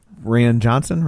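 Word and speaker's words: Rian Johnson: Ryan Johnson, right? – Yeah. – Did I say it right? Yeah, yeah. – Yeah, Ryan. – Ryan.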